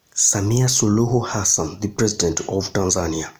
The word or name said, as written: Samia Suluhu Hassan